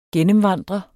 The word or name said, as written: gennemvandre